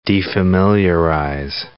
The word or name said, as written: defamiliarize